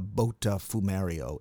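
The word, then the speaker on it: Botafumeiro: Botafumero. Am I pronouncing it correctly? – Yep, you got it.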